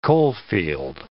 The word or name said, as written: coalfield